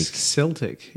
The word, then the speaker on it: Celtic: Celtic, yeah. – Celtic. Yeah. He said it's a hard K sound.